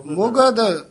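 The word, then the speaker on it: मुगध: ये पाठ ध्यान नाल उच्चारण करना है। मूर्ख मुगद ये जेड़ा मुगद पादन बड़ी बार आउंदा आप आम ज्यादा मुगद ही बोल जांदे। मम्मी औंकड़ गग्गा ददा मुगद मम्मी औंकड़ गग्गा धद्दा मुगद ते अज्ञान मूर्ख मुगद अज्ञान अविचारी पाठ करना है।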